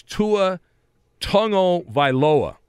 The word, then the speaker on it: Tua Tagovailoa: And the quarterback at Alabama, it's Tua Tagovailoa.